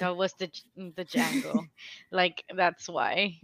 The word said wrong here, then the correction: Django: The D is silent. – The D. Django. Sorry. Yeah. Django. Django. Django.